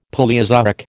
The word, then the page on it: Palaeozoic: American English